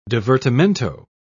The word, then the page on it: divertimento: British English